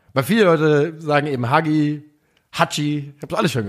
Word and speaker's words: Hagi: Also man muss sagen, Hagi, spricht man das Hagi oder Haji? – Haji.